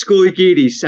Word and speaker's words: Skolikidis: It's Skolikidis. I mean, it's just very Greek with a lot of vowels in there, though.